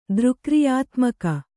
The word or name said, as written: ದೃಕ್ರಿಯಾತ್ಮಕ